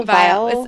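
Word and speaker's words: Viall: Vile. – They say like vile. It sounds, you pronounce it like vile, V-I-L-E, which makes sense.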